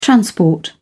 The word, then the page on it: transport: British English